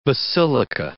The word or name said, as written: basilica